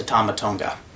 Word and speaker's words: Tama Tonga: I apologize to Tama Tonga.